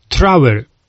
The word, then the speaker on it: Trower: Jeżeli macie Państwo taką możliwość, to na YouTubie, w wyszukiwarce YouTube'a wpiszcie sobie po prostu Barry Trower. Pisze się to B-A-R-R-I-E, Barrie, tak mówiąc po polsku, i Trower, T-R-O-W-E-R.